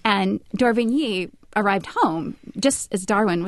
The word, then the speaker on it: d'Orbigny: Yeah, Alcide d'Orbigny. – Is that how you say it? – Orbigny? D'Orbigny? – D'Orbigny, maybe.